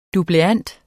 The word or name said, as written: dubleant